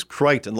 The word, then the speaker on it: Crichton: But it is Crichton, like, you know, the famous author, Michael Crichton.